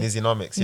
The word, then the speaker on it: Nizzynomics: Did I say it correctly? – Nizzynomics. – Nizzynomics. – Yeah.